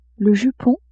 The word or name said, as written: jupon